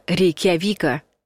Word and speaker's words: Рейкьявик: Запоминайте, Рейкьявик нужно произносить твердо через «э» и с ударением на букве «я».